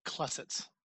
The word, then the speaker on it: Klesitz: Frank Klesitz.